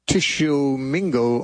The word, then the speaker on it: Tishomingo: Our next caller is Dallas from Tishomingo, Oklahoma. Never heard Tishomingo.